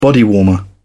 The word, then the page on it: bodywarmer: British English